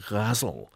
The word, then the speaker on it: ghazal: called the ghazal, G-H-A-Z-A-L, Ghazal. It's pronounced in different ways. Ghazal, Ghazal. It's actually related to the word gazelle, apparently.